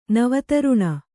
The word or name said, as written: ನವತರುಣ